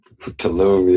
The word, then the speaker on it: Tulum: I'm saying it right, Tulum? – Tulum, yeah.